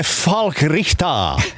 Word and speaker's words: Falk Richter: Vem är Falk Richter? – Du kör hårt i det tyska uttalet.